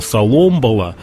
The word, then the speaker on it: Соломбала: Да, Соломбала. По-разному называют, но правильное ударение – Соломбала.